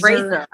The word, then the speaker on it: Fraser: He said his name is pronounced Brendan Fraser. – Oh, really? – Fraser. – Well, Fraser. – He made like a joke. He made like a silly YouTube. Like, everybody mispronounces my name. It's Fraser.